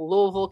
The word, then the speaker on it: Louisville: And so Rebecca's coming to us from Louisville, Kentucky.